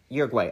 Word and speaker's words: Uruguay: It carried a crew of five and 40 passengers, members of the rugby team from Montevideo, maybe, in Uruguay, or Uruguay? No, crap. Uruguay. Uruguay. Okay, I said it right the first yeah.